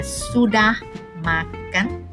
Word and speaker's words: sudah makan: We just say it as sudah makan. Sudah makan, S-U-D-A-H-M-A-K-A-N. Now, the Malay language is actually pretty easy. It's being read phonetically as how it's being spelled.